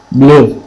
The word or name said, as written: blu